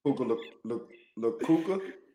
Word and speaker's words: Puka Nacua: I think you're getting Puka Nacua and Luka Doncic mixed up, but yes. – Puka Nacua, I'm sorry. Nobody thought Puka Nacua.